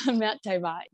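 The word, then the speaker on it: Mount Tomah: So you are the senior horticulturist in the nursery at the Blue Mountain Botanic Garden at Mount Toma. Am I saying that correctly? – Yes, that's correct. Mount Toma.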